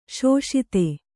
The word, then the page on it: ಶೋಷಿತೆ: śoṣite